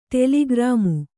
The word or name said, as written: ಟೆಲಿಗ್ರಾಮ್